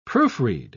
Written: proofread

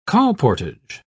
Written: colportage

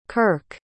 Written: kyrk

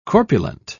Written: corpulent

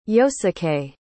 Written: yosuke

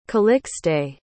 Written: calixte

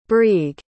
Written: brieg

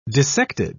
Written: dissected

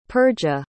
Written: Perga

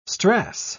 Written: strass

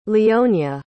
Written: leonia

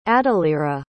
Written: adalira

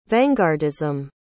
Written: vanguardism

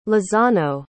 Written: lozano

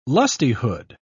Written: lustihood

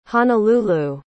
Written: honalulu

